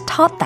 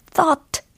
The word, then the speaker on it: taught: I was taught that where there is a will, there is a way. 네, 잘하셨습니다. 숨가쁘게 마무리를 해봤습니다. 어, 특히 taught 라고 발음을 하셔야지 thought 라고 하시면 안 되는 거 알고 계시죠?